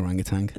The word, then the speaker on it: orangutan: We call it the orangutan. – The orangutan. Is it? Would you say it's not? You don't pronounce the G, you know.